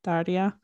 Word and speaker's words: Daria: Daria. – Fans. – Roll the R.